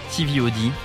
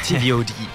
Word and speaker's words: TVOD: Il faut le dire avec la prononciation avec anglaise. – Donc, un concert de TVOD. – Yes. – Exactement, From the USA, TVOD.